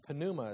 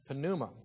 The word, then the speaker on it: pneuma: Pneuma is how you say that word. Pneuma.